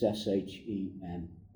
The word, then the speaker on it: Shema: And I think I've pronounced it right. It's Shema.